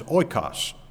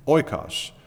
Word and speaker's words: oikos: The Greek word for house is oikos. All right? Oikos. I think I have that on the overhead someplace, in case you're wondering how you spell it. Transliterated, at least. This is how you use O I K O S, oikos.